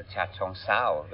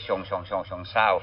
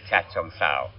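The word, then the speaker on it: Chachoengsao: Ja, Bangkok angränsar till ett ställe som heter Cha Chong Sao, väldigt svårt uttalat, det tog mig 6 år att lära mig det. Jag sa alltid Chong Sao förut, men det heter Cha Chong Sao.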